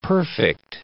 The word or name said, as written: perfect